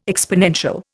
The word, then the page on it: exponential: Indian